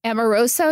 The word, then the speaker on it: Amoruso: Amoroso, am I pronouncing her name right? From from Girl Boss. – I always butcher her name too. – I think that's correct.